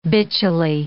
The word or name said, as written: bitchily